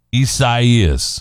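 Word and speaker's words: Isaías: Isaias. – Isaias.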